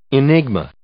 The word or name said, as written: enigma